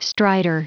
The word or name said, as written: strider